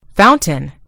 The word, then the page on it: fountain: British English